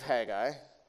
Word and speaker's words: Haggai: The book of Haggai. So you got to flip towards the end of your Old Testament now, okay? Over to the book of Haggai, towards the end, H-A-G-G-A-I. Some people pronounce it Haggai.